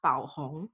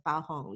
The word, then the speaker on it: Baohong: It's actually pronounced Bao Hong, which is a Chinese brand, but you know, Bao Hong, you can read it that way.